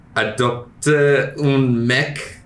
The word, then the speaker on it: Adopte un mec: It's called, French pronunciation. Here we go. Adopte un mec.